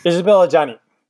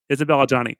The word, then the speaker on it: Isabelle Adjani: The player is played by, how do you say her name? Isabelle Adjani. Thank you, Isabelle Adjani.